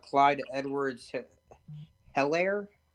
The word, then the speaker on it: Clyde Edwards-Helaire: I mean, you can't forget about Clyde Edwards-Helaire.